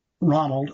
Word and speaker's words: Ronald: It's spelled Ronald, R-O-N-A-L-D, H-O-G-R-E-F-E.